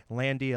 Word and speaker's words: Landi: Landy, L-A-N-D-I.